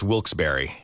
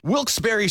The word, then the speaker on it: Wilkes-Barre: Now, I had heard that it was supposed to be pronounced Wilkes-Barre. – Wilkes-Barre Scranton's 97.9X. The only way to pronounce Wilkes-Barre.